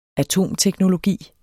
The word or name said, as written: atomteknologi